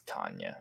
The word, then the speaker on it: Tania: Spelled T-A-N-I-A, and then I looked at the pronunciation, and it's Tanya.